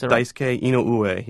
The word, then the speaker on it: Daisuke Inoue: His name, if I'm pronouncing it right, is Daisuke or Daisuke Inoue? – It's Daisuke Inoue, yeah.